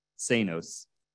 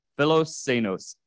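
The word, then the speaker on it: philoxenos: But it's the Greek word philosenos, and I'm going to spell it for you. It's P-H-I-L-O, and that's kind of the first word. I'm going to, as I spell it, kind of note that that's the first word, P-H-I-L-O, and it's combined with the ending, which is X-E-N-O-S, senos. So philosenos, and we're going to go into each of those words.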